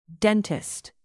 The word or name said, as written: dentist